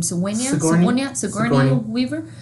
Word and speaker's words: Sigourney Weaver: Sigourney Weaver.